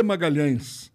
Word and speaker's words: Magalhães: Eu comecei a me apresentar em vários locais e o meu nome é Sidney de Magalhães. E o Magalhães na Itália não é pronunciado, o LH não existe, então era Magalhães.